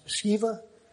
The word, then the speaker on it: Sceva: There's a story over there that's told about seven sons of, I pronounce his name is Sceva.